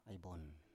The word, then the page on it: ai bon: Biak